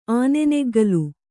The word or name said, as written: ಆನೆನೆಗ್ಗಿಲ್